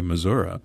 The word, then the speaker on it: Missouri: And then I recently heard Senator McCaskill answer the question, "How do you pronounce the name of the state?" And she said, "I pronounce it both ways." She didn't skip a beat, you know. So I've noticed that politicians, white politicians, will sometimes say Missouri, sometimes say Missouri.